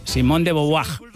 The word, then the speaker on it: Simone de Beauvoir: Pronunciado en francés, Simone de Beauvoir.